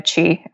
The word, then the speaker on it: Qi: It's at Christina Qi, uh, which is spelled Q I.